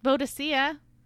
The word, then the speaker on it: Boadicea: Boadicea.